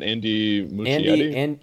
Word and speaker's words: Muschietti: Is it Andy Muschietti? – Yeah, that's right.